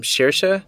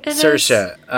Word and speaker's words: Saoirse: Shersha. – Saoirse, I think. – Saoirse.